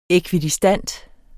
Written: ækvidistant

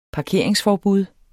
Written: parkeringsforbud

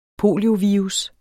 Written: poliovirus

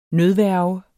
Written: nødværge

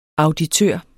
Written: auditør

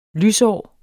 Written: lysår

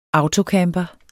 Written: autocamper

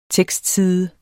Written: tekstside

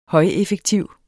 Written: højeffektiv

